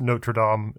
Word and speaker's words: Notre Dame: I can see it's there's actually like a hard there's a R in there that's very hard to pronounce for us Americans. But the I think general approved pronunciation for that cathedral is Notre Dame in American English.